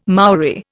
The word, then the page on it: Maori: Indian